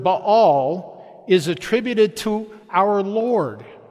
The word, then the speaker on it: Baal: That's what we call Baal.